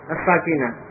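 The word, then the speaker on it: as-sakinah (الساكنة): Ya, juga perhatikan ya, membacanya yang panjang sinnya as-sakinah, yang bermakna apa? Sukun, yang bersukun. Jadi huruf yang bersukun itu sakin. Tapi kalau sakinah ya, ini maknanya ketenangan, ya, ketentraman hati atau ketenangan. Berbeda ya. Membacanya juga diperhatikan panjang pendeknya, jangan keliru, jangan terbalik.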